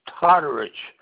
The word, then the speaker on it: Todorich: The last name is Todorich. That's spelled T-O-D-O-R-I-C-H.